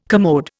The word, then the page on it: commode: Indian